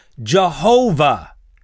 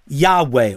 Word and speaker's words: Yahweh: For some years, those letters YHWH were mistakenly pronounced as Jehovah instead of Yahweh or Yahweh.